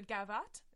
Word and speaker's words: gavotte: I can do a gavotte. Is that how you say that? – Sure. – The dance? – Yeah.